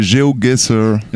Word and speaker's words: GeoGuessr: Donc c'est GeoGuessr. – Voilà, Géo, la géographie, et Guessr, ouais.